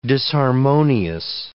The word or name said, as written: disharmonious